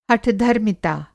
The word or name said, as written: हठधर्मिता